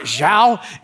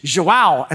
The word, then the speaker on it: João: But I said João, and somebody said, it's not João, it's João. And I go, João? And they go, no, it's João. And I'm like, I just said that, right? So it's like João.